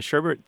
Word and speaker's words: sherbet: The sherbet thing pronunciation is so widespread that some places have, as you might expect, where I'm going with this, have just given up and go ahead and spell it with that second R, even though it's not required.